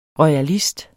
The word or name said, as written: royalist